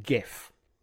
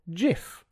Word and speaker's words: GIF: So, you're probably familiar with GIF,